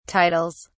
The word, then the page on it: titles: British English